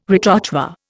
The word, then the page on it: retrochoir: Indian